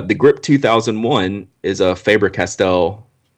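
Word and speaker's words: Faber-Castell: I want to say Faber Castle, but I know it's it's probably Faber Castell or I would you call know, it Faber Castell.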